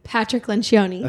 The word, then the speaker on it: Patrick Lencioni: Patrick Lencioni.